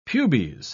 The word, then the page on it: pubes: British English